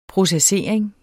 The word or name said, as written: processering